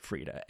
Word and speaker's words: Frida: I think you pronounce it Frida. F-R-I-D-A.